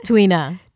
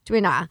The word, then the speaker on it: Tuina: Tuina. – Tuina.